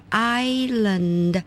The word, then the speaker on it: Island: 还有像我们说台湾是个宝岛，这个岛屿呢叫 Island, 但是拼法是 I-S-L-A-N-D, 那个 S 也是没有发音，所以特别注意到我们现在学的这个 yacht Y-A-C-H-T 那个 C-H 是没有发音的。